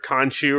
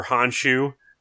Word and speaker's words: Khonshu: Right before he passed out, he was like, and crawled up to a shrine, and it was a shrine dedicated to either Khonshu or Honshu. I don't know if the K is silent. Sometimes it is, sometimes it's not.